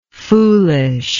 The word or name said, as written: foolishness